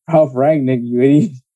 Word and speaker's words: Rangnick: Rangnick. What's so hard about that? – Well, how'd you say it? – Rangnick.